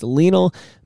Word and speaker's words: Lionel: I want to say Lionel, but it's not like Lionel Hutt's attorney at law. It's Lionel Messi.